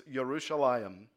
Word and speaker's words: Yerushalayim: The Hebrew word for Jerusalem is Yerushalayim.